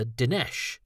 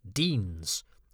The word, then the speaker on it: Denes: was called Alexander Dinesh. When I had read the book, he had been the far more prosaic Deans. That's how it looked. D-E-N-E-S, so I had no idea it would be pronounced otherwise.